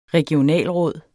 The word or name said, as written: regionalråd